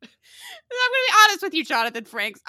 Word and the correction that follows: Frakes: And did I just say Jonathan Franks? I definitely meant Frakes.